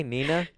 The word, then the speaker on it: Neenah: N-I-N-I-N-A, Neenah? – No. Not the name, oh. Neenah, but it's N-E-E-N-A-H. – Oh, but it's pronounced Neenah. – Neenah.